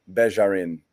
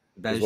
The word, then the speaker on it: Begarin: Juhann Begarin. – Begarin.